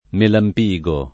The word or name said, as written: Melampigo